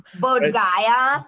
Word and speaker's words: Bodh Gaya: I went to the Bodh Gaya, which is where, you know, Buddha sat under the tree and found enlightenment or whatever and became him. – Bodh Gaya.